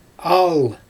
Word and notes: aal: Xyzyl

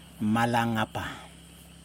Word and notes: malaNapa: Yokoim

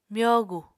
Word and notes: myogu: Apatani

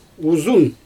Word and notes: uzun: Xyzyl

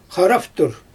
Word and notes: xaraftur: Xyzyl

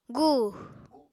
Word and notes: gu: apatani

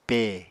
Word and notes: pe: Apatani

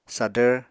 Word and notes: sadvr: Apatani

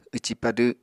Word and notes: wci-padw: Apatani

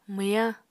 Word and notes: mwyañ: Apatani

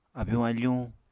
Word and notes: abyuñ-afuñ: Apatani

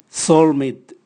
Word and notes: solmit: Raga

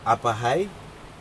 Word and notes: apahai: Aneityum

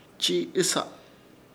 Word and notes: Chiiɨsa: Ishir Chamacoco

